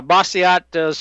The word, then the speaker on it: Bastiat: Yeah, Bastiat, some people say it's more of a French pronunciation, but who cares? – All right.